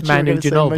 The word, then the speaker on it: Ginobili: Manu Ginobili.